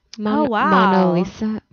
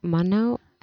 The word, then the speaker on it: Mona Lisa: Mona Lisa. Mono? I said that wrong.